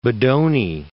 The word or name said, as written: Bodoni